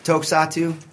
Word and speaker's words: Tokusatsu: So being a fan of like early Tokusatsu, is that how they pronounce it right? Tokusatsu? – I think so.